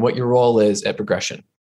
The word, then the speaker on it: Progrexion: I'm your host for today, and I'm speaking with Dr. Ken Knapton, Chief Information Officer of Progrexion. Uh, it sounds like Progression, it's spelled P R O G R E X I O N, just to be clear.